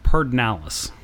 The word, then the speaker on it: Pedernales: I don't want to actually you, but even though it is spelled Pedernales, it is Pedernales.